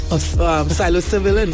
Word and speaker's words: psilocybin: Psilocybin, that's how you pronounce it? – Yeah.